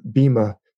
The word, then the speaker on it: Bhima: Bhim is kind of a Hercules figure, Bhima. It's spelled Bhima, B-H-I-M-A. They usually pronounce it Bhim,